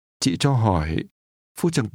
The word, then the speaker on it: Hỏi: Hỏi. Hỏi. Hỏi. – This word is pronounced with a falling, rising tone. – Hỏi.